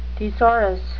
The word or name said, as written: thesaurus